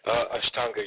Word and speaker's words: Ashtanga: Astanga, right. – Am I saying that right? – Ashtanga, yeah, but it's perfectly fine.